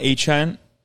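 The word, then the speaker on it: Achane: Devon Achan, which is how he wants his name pronounced now. – Achan. – I don't know why. Like, does that look like H-hand to you? – No, it looks he like had H-hand.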